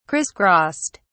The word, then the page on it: crisscrossed: British English